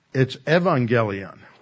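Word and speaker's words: euangelion: But that upsilon is sort of like the German pronunciation of W. It's pronounced by the Greeks as a V. So it's evangelion, which is where we get our word evangelism. Okay? So that's why I tend to pronounce it with a V, and people look at it and go, I don't see the V. Where's the V? The V is the upsilon.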